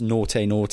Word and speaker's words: Nortei: N-O-R-T-E-I. – Yeah. – N-O-R-T-E-Y. – Oh, so it's spelled... – I think it's pronounced naughty. Naughty.